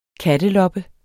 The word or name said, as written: katteloppe